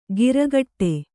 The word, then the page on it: ಗಿರಗಟ್ಟೆ: giragaṭṭe